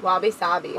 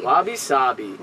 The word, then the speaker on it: wabi-sabi: Wabi-sabi. – Wabi-sabi.